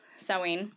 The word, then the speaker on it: Samhain: Happy Samhain, everyone. – Which is interesting. – Samhain, yep.